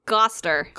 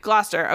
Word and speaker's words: Gloucester: How do you say the word? – Gloucester. – Gloucester. Okay, Miles de Gloucester and his partner, Payne Fitzjohn, were both dudes.